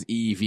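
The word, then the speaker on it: Evey: V notes that Evie's name is special, pronouncing it as EV is the fifth letter of the alphabet, and V is Latin for five.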